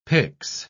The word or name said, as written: pyx